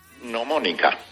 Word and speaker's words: gnomónica: ¿se dice bien gnomínica o lo digo mal? – Gnomónica. – Gnomónica, o sea, sin decir la G, como si fuera gnomónica, ¿no? – Sí, señor.